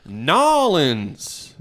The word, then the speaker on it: New Orleans: I believe it takes place in New Orleans. That's how the locals say New Orleans.